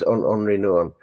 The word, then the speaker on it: Henri Nouwen: It's spelled Henry, but it's Henri Nouwen.